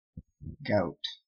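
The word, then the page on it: gout: Canadian English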